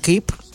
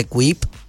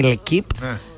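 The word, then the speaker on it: L'Équipe: Ναι, τώρα βγήκε, ας πούμε, στο ότι και η L'Equipe, γιατί έτσι προφέρεται, όχι L'Equipe, είναι η L'Equipe.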